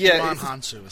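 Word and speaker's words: Djimon Hounsou: Juman Hansu is how you say it.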